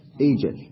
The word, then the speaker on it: أوجل: قلنا تبدل الواو ياء فتكون إيجل. لماذا؟ لأن ما قبلها مكسور.